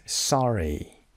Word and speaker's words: sorry: Next word is sorry. Sorry. پس استرس قسمت اوله. It's on the second. Okay. It's on the first syllable. So we say sorry و نه sorry.